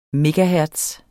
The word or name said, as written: megahertz